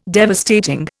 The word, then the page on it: devastating: Indian